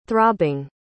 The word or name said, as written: throbbing